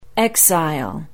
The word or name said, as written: Exile